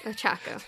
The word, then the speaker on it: Ochako: How do you say it? Yororako? – Ochako.